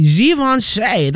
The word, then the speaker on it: Givenchy: And not only did she find success in music, she also was formerly a high fashion model for brands like Gucci, Givenchy, that is how you say it.